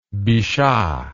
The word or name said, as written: bichat